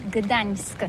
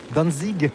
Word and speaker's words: Gdańsk: très facile pour des étrangers, mais remarque bien que la langue polonaise, c'est la langue où on prononce chaque lettre qu'on écrit. C'est pourquoi on dit Gdańsk.